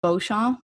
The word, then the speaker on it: Beauchamp: And the reason is, of course, comes back to Childress and Beauchamp, which is spelt Beauchamp, by the way, like B-E-A-U-C-H-A-M-P, if anyone like is just dying to check them out after this.